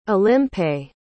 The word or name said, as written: Olympe